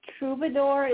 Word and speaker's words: troubadour: Well, I will say that troubadour is actually one of those rare words in English that we pronounce fairly close to, I think, the French pronunciation.